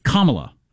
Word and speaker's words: Kamala: Kamala is that right? Yes. Kamala Harris. Yes, like the, the the little hook thing that separates words and punctuation.